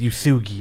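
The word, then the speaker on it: Uesugi: Nino, the tsundere, and pretty much hates Usagi. It's Usugi. I've been saying the name wrong the entire time.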